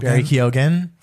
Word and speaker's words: Keoghan: Barry Keoghan.